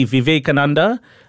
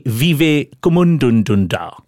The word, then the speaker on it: Vivekananda: He mentioned the great spiritual and Vedic scholar Swami Vivekananda. Tough name to pronounce, but he went with a special pronunciation. He called him Swami Vivekumundundunda, which again is an important Taekwondo class in Bangalore.